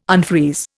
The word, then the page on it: unfreeze: Indian